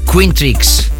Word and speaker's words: Quintrix: Quintrix, se escribe Q-U-I-N-T-R-I-X.